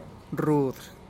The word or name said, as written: rud